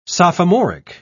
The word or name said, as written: sophomoric